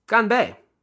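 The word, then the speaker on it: ganbei (干杯): Well, it's ganbei.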